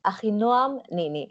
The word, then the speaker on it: Ahinoam Nini: Ahinoam Nini. – Ahinoam Nini, yes.